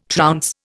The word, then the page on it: trounce: Indian